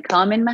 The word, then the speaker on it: Kamin: I'm Kamin Mohammadi.